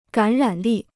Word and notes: 感染力: gǎn rǎn lì

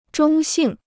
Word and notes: 中性: zhōng xìng

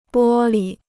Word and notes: 玻璃: bō li

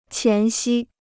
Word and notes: 前夕: qián xī

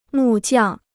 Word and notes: 木匠: mù jiàng

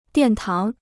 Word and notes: 殿堂: diàn táng

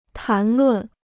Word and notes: 谈论: tán lùn

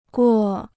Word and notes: 过: guo

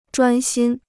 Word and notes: 专心: zhuān xīn

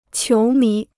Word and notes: 球迷: qiú mí

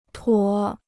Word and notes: 妥: tuǒ

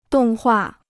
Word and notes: 动画: dòng huà

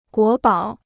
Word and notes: 国宝: guó bǎo

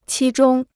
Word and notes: 期中: qī zhōng

